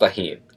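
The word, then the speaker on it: Tajín: It's pronounced tahin. Get it right.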